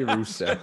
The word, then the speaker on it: Russelle: Is it Russell or Russelle? – I've heard both. I don't even know if I say it right, but it's Russell for all I know.